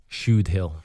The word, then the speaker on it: Shudehill: Do I say it right when it's called Shudehill? – Shudehill.